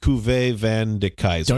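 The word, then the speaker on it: Cuvée van de Keizer: And I believe it's pronounced Cuvée Van de Kaiser.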